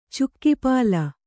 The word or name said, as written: ಚುಕ್ಕಿಪಾಲ